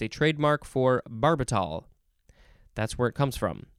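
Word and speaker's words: barbital: Yes, B-A-R-B-I-T-A-L. I want to say barbitol, but I think it's pronounced barbitol.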